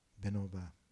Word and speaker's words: Vinoba: After him, am I saying this right? Vinoba. Vinoba, thank you.